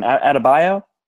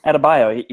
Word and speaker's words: Adebayo: Uh, I think it was um, Bam Adab- How do you say his last name? Adebayo. Yep, that, that's right. Adebayo.